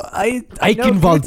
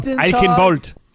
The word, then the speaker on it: Eichenwald: Eichenwald? – That's not that. – Really? – That's right. – Maybe. I don't know. – Eichenwald. Eichenwald.